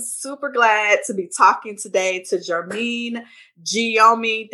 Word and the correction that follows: Guillaume: Did I say that right? – It's Guillaume, but it's okay. – Guillaume. See, that's yes. Why I apologize, because I'm like, I know I'm a butcher it.